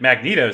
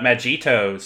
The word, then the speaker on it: Mygeeto: I originally pronounced it Magneto system because it's spelled so weird. The Mygeeto system.